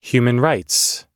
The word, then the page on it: human rights: American English